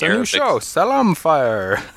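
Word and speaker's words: salam (سلام): Well, I did a little bit more digging, and it turns out the way you pronounce the word peace in Arabic is salam.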